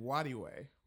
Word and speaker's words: Nwadiwe: Nwadiwe.